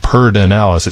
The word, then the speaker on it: Pedernales: We pronounce things kind of in a strange way sometimes here in Texas. It says, we pronounce it Pardinalis. It's spelled P-E-D-E-R-N-A-L-E-S.